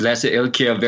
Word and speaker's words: Lasse Elkjaer: And yes, it's my name is Lasse Elkjaer, very hard to pronounce, but you did good.